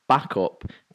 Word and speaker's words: Bacup: However, he's located in Backup, B-A-C-U-P. So I thought... – Backup. – Is that how it's said? – Backup.